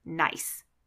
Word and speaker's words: Nice: Now, if you don't know geography very well, Nice is in France, which is also spelled like the word nice. But I promise it's pronounced Nice.